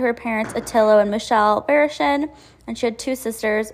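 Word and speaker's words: Varaschin: I hope I pronounced that right. I did look it up. I'm going to go with Barishin.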